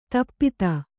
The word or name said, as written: ಧೂಪಿತ